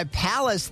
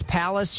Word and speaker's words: Pallas: Our oldest, Evelyn Jean, goes by Palace these days. P-A-L-L-A-S kind of rhymes with Dallas. Palace, she came up with it on her own.